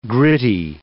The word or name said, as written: gritty